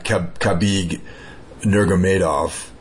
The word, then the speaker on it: Khabib Nurmagomedov: I mean, he was supposed to fight Khabib Nurmagomedov. – Yeah. – Is that how you pronounce it? I always have problems with his last name. You nailed that one, yeah.